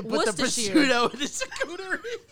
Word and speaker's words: charcuterie: A charcuterie board.